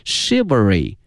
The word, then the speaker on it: chivalry: 今天我们学到了侠客的这个英文的翻译，它的发音是 chivalry， chivalry。同时啊同学们注意啊，这个词的发音哈，它跟一般的规律不一样哈，这个 ch 没有念成这个七这个音，而念成这个诗这个音，所以同学们要小心哈，念成了 chivalry novels。